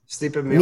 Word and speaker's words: Miocic: Stipe Miocic.